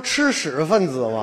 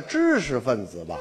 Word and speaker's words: 知识分子: 你不说吃屎分子吗？ – 你不是吗？ – 谁呀？知识分子吧？ – 啊？